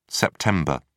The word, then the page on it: sept: British English